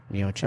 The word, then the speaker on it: Miocic: Miocic.